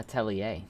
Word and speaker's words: Atelier: It's the Adler series. – Atelier. Let's see how we pronounce it. Google, pronounce this word for me. Arceus. – Atelier. – Ah, Atelier. It's French.